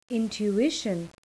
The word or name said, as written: intuition